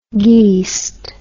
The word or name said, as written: geest